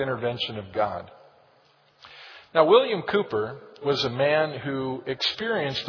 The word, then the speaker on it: Cowper: His name is spelled in our hymn book, C-O-W-P-E-R, but he was from England and they pronounced that Cooper.